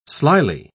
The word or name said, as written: slyly